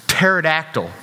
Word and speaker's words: Pterodactyl: Pterodactyl, but the P is silent.